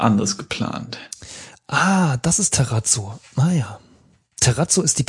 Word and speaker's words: Terrazzo: Wahrscheinlich wird das auch ganz anders ausgesprochen, so wie Terrazzo. – Nee, nee, ist schon Terrazzo.